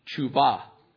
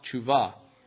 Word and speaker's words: teshuva: It's spelled, it actually is looks like teshuva, but it's pronounced tshuva.